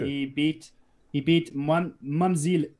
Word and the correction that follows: Mzwandile Hlongwa: let me say it properly, Mzwandile Hlongwa, and he beat him by a Das choke.